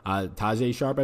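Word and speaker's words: Tajae Sharpe: Brown has taken over the number 2 job from Tajon Sharp, whatever it is. I said that guy's name like he was like Chinese or Japanese, but he's not. Tajay Sharp, I believe it is pronounced.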